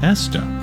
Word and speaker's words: Estha: In around 2024, Eric co-founded an enterprise AI platform called Esther, It's spelled ESTHA.